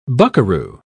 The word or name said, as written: buckaroo